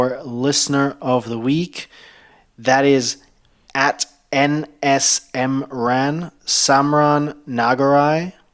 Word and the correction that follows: Nagaraj: Am I saying that correctly? – I think it's Nagaraj. – Nagaraj, sorry.